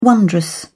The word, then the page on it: wondrous: British English